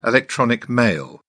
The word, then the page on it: electronic mail: British English